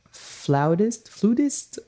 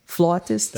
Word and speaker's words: flautist: who's a great jazz flutist. How do you say that? – Flautist. That's how we would say it on this side of the pond.